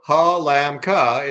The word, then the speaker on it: Halamka: The answer, of course, is depends on the country you're in. And so Halamka is how a, a U.S. person would say it.